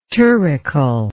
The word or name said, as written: turrical